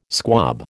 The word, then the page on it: squab: American English